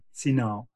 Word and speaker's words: hsinao: H-S-I-N-A-O, Sinao, means the cleansing of the heart.